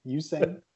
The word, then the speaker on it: Usain: Usain. – You, Usain. Usain. You, like a hard U. Hard U.